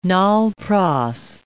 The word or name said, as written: nol-pros